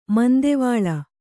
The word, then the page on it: ಮಂದಿವಾಳ: mandivāḷa